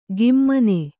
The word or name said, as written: ಗಿಮ್ಮನೆ